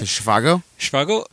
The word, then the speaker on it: Zhivago: But she's from Dr. Zhivago. Is that how you say it? – Zhivago.